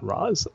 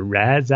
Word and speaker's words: Ra's: Well, if he's going to start calling me detective, I got to make sure everyone calls him by his proper pronunciation, Ra's, and not Roz. That's people still pronounce it as, which still, yeah, you know, bugs me a bit, I got to say.